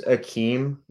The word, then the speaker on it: Hakeem: It's pronounced Hakeem. Like, it's just the littlest bit of the H.